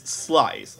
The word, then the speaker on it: Slies: But no, you corrected me, and it's like, no, it's Slies, like guys.